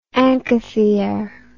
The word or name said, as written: anchithere